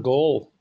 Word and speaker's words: Gohl: My name is Warren Gold. The name is spelled G-O-H-L.